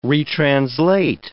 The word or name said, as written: retranslate